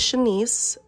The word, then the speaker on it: Shanyce: My name is Shanice, spelled S-H-A-N-Y-C-E, but all my life it has been butchered.